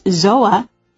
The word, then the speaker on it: TZOA: A new wearable EnviroTracker called TZOA, pronounced ZOA, uses internal sensors to measure air quality, temperature, humidity, atmospheric pressure, ambient light, and UV, sun, exposure.